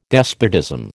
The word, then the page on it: despotism: American English